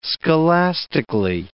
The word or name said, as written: scholastically